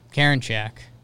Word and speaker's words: Karinchak: Karinchak.